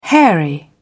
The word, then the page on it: hairy: British English